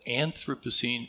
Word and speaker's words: Anthropocene: If there's any doubt about the pronunciation of this word for many of you, I'm told from the geophysicists that Anthropocene is the correct word to use, or the correct pronunciation that I can't pronounce today.